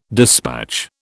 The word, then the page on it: dispatch: British English